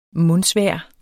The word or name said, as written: mundsvejr